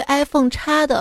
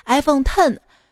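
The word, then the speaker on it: iPhone X: 库克对 iPhone 叉的发音是 iPhone Ten。” 这不是英语字母的 X，是罗马数字的十，是为了向 iPhone 发布十周年致敬的。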